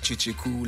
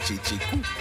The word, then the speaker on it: Kyekyeku: My stage name is Cheche Ku, and it's written K-Y-E-K-Y-E-K-U, but pronounced Cheche Ku like two Che Guevaras and one Cool in the gang, so that makes Cheche Ku.